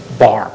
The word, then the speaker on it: Bahr: Walter Barr, 33639 East Hunter's Run in Palouse. – What'd you say your last name was? – Barr, B-A-H-R.